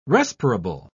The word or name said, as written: respirable